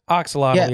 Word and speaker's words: axolotl: Like you were talking about those axolotls, or what is that, how you say it? The axolotl.